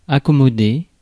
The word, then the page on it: accommoder: /a.kɔ.mɔ.de/; France (Paris)